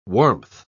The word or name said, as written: warmth